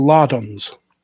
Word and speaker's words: lardons: It's called lardons. – Is it? – Yeah. Okay. It is. Not with a H. There isn't a silent H on the beginning of that.